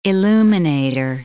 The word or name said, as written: illuminator